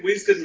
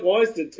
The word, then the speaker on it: Wisden: It just looks like Wisden to me. – Oh, how does it look like Wisden? It's Wiz. Dead. – There's no Z there. Why do I pronounce it like a Z? – Oh, leave this man on.